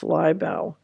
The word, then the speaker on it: Laibow: And then Dr., I think it's pronounced Laibow, Laibow.